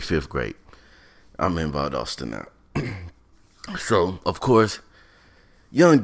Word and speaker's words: Valdosta: Valdosta, that is the proper pronunciation for those of y'all who don't know, is Valdosta.